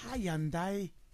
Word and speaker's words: Hyundai: Yeah, and there were two gentlemen who said that that's how they pronounce it. – Hyundai.